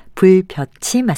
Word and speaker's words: 불볕: 불볕은 몹시 뜨겁게 내리쬐는 햇볕을 뜻하는데 불볕이 아니라 예사소리로 발음하는 불볕이 맞습니다.